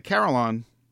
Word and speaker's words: carillon: All right, the carillon, spelled like it sounds, not like the planet in Battlestar Galactica.